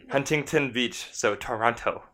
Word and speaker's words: Toronto: I say, I think the locals say Toronto, but like... – Toronto. – Toronto. – No, it's Toronto. That's how you say it.